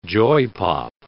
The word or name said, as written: joypop